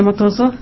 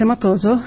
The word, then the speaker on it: Matoso: I spelled, said your name wrong. – Marcia Matoso. – Oh, Matoso. Forgive me, please. Go right ahead. – For the record, my name is Marcia Matoso, and I am a marriage and family therapist and also certified gambling addictions counselor.